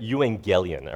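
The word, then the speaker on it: euangelion (εὐαγγέλιον): Well, if you ever thought about what the gospel actually means, the word itself, well, in Greek, the word is, I don't like speaking Greek from here, but euangelion, all right? That's how you say it.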